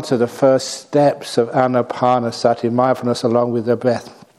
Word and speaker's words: anapanasati: Anapanasati, mindfulness along with the breath. It's not pronounced anapanasati, which is mindfulness along with pain.